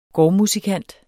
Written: gårdmusikant

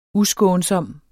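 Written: uskånsom

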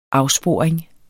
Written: afsporing

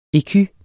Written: ecu

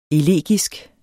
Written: elegisk

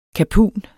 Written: KAPUNENS

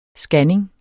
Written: scanning

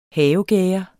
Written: havegærde

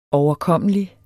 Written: overkommelig

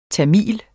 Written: tamil

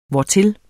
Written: hvortil

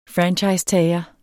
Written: franchisetager

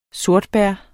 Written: sortebær

